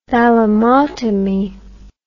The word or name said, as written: thalamotomy